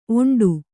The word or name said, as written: ಒಂಟಿ